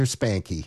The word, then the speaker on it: Spanke: His name spelled S-P-A-N-K-E. – Well, we consulted the Oracle, Deke Dickerson, and he got right back to us. – And he said most people in Bakersfield pronounce the E, so there we are, Roger Spankey.